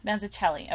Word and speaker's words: Mazzucchelli: Yeah, Mazzucchelli.